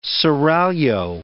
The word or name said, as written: seraglio